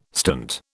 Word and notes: stint: British English

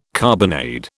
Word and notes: carbonade: British English

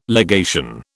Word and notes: legation: British English

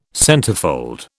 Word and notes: centerfold: British English

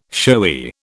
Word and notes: showy: British English

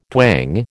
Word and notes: twang: American English

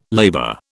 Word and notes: labor: British English

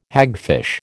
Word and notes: hagfish: American English

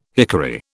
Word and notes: hickory: British English

